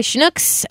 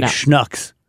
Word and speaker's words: Schnucks: I was reading over your shoulder, and I saw on your Twitter someone saying, you're all saying Schnucks wrong. – Oh, you don't know what you're talking about. – It rhymes with ducks, like Schnucks.